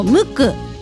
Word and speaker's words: MUQUE: バンド名のこのムク、 MUQUE と書いてムクなんですけれども、これフランス語なんですね。